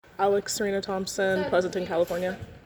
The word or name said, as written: Alexandra Surrena-Thompson